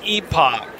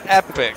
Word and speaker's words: epoch: Well, if you're British, it's epoch. if you're American, I'm not British. – If you're American, it's epic.